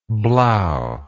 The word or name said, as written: blaeu